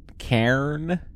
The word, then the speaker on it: Cairn: What is what is it? Uh, I think it's Karen. Cairn. Yeah. K C A I R N. Right. Is how it's spelled